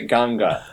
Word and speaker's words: Ganga: So they pronounce it Ganga.